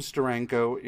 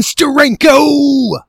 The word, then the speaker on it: Steranko: I absolutely love, there is a scene in one of the Jim Steranko issues. Of no, sorry, you're saying it wrong. You have to say Jim Steranko.